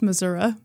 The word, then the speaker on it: Missouri: And it's pronounced Missouri.